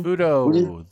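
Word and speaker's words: Fudoh: So that is me going with, how do you pronounce it? Fudo? – Fudo, the Fudo. New generation.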